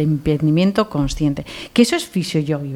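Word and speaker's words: Fisio Johi: Pero se escribe con J-O-H-I. Fisio Yogi.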